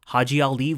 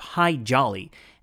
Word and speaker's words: Haji Ali: When the Americans showed up asking for camels and those with camel know-how, Haji Ali volunteered to travel to the U.S., and it's here that he takes on the moniker of High Jolly, as the Americans commonly mispronounced his name.